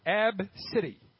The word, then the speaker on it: ABCDE: Abcedi? – What do you think, Sergeant Steve? Take a guess. – Abcade? – The mother said it's pronounced Abcity. A-B-C-I-T. Abcity. A-B-C-D-E.